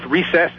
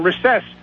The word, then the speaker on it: recess: And what usually happens is when I look it up, I realize there's an alternate pronunciation, and both recess and recess are both acceptable pronunciations. – But there's one that's listed first, though, and I would guarantee you that is recess.